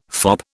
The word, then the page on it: fop: British English